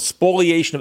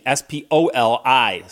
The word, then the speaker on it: spoliation: It's called spoliation of evidence. Spoliation, S P O L I, spoli, not spoliation, spoliation.